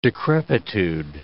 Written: decrepitude

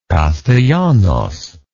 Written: Castellanos

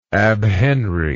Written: abhenry

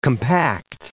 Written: compact

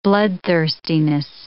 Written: bloodthirstiness

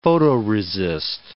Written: photoresist

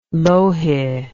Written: louhi

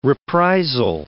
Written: reprisal

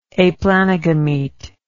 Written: aplanogamete

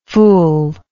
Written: ful

